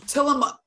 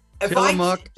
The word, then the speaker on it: Tillamook: But friend said it's pronounced as Oregon and Tillamook. Tillamook and Oregon. – I know Tillamook now. Set me straight on that.